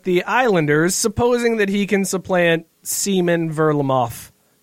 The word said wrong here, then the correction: Semyon Varlamov: Ver, Verlamov. Did I say that right? Seaman? Varlamov. V- Seaman Varlamov. My my. – Semyon bad. Semyon Varlamov.